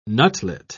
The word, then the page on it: nutlet: British English